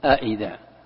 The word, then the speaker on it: أئذا: أي تنطق بالهمزتين جميعا، فتقول أئذا أئنا، ويجوز لك التسهيل، تسهيل الثانية بمعنى أنك تسهل الثانية ما تنطقها، لكن تجعل فيها مد، قال إدخال ألف بينهما على الوجهين تسهيل. كيف التسهيل؟ تقول قالوا أئذا قالوا أئذا قالوا أئذا كنا قالوا أئذا متنا أئذا متنا أئذا تسهيل.